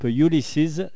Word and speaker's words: Ulysses: Donc, euh, on reçoit le groupe Ulysses. C'est ça, ça se prononce comme ça.